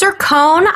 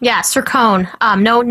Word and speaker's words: Sircone: It's Sircone. Sircone. No qua sound in there.